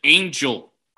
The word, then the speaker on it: Enyel: You spell his name E-N-Y-E-L, but you pronounce it Angel.